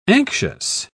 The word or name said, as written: anxious